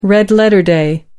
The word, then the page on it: red letter day: American English